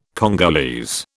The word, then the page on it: Congolese: British English